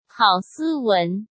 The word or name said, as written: Hao Siwen